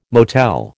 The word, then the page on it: motel: American English